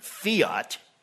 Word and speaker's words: fiat: We sometimes speak of God's eternal decree as his fiat, F-I-A-T, fiat. It's spelled exactly like the automobile brand Fiat, but it's actually a Latin word that is pronounced fiat.